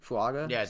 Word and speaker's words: Fuaga: I hope I said that right. Do you guys know how to pronounce it? Fuaga? – Yeah, you did it right.